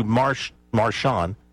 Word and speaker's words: Marchand: Andrew Marchand. Let's don't give it a French pronunciation. Andrew Marchand.